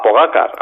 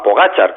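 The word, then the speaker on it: Pogačar: a Pogacar, a Pogachar, como también se le, se le pronuncia, ¿no?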